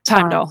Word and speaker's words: Heimdall: Heimdall actually, throughout the entire franchise, Heimdall manages to, is it Heimdall? Am I saying it wrong again? – Heimdall, yeah. – Heimdall.